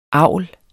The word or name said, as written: avl